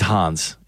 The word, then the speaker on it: Hans: It's he's Hans. He's German. – I was trying to rhyme with fan.